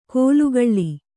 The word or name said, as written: ಕೋಲುಕಳ್ಳಿ